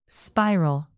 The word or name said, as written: spiral